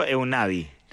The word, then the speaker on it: Einaudi: Enrico Ludovico Einaudi se llama, no, ¿cómo se llama? – Einaudi. – Eso. – Ludovico Einaudi.